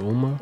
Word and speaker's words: Umar: Omar. Is that am I saying his name right? – Is it Dr. Umar? – Dr. Omar.